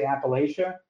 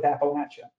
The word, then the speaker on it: Appalachia: So I've found that the people who say Appalachia are more passionate about the pronunciation than those who say Appalachia. So I stick with Appalachia. But I've heard both, and I think that both are correct.